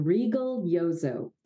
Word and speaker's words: Rigoglioso: it's regal like a queen, Rigoglioso. That would be the absolute Italian pronunciation of it, but you're good.